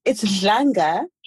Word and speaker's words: Dlanga: It's Dlanga. – Dlanga. I'm going to get it. Okay. – Yes. You can say Dlanga. You just so that, you know, people spell it right. D-L-A-N-G-A.